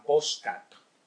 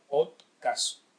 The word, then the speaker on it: podcast: Es decir, que si es podcast, eh, no digan postcat, postcat, eh, pero no se coman las letras porque realmente suena horrible, eh, horrible. No, no pretendo yo dar lecciones de, gramática, pero bueno, si, o, de pronunciación. Es decir, no hace falta podcast. Es una palabra que curiosamente se pronuncia igual en inglés que en español se lee igual, podcast, podcast, que viene de iPod.